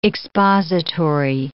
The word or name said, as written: expository